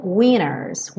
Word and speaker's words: winter's: Eu pintei esse T de cinza como uma letra não pronunciada porque na gravação original eles falam winners, winter's day. É um sotaque bem americano de reduzir tanto o T que ele some.